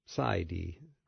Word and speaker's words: Psy D: George, I just had to check Rona's credentials, and she is a Psy D, right? Did I say that right? – Yes.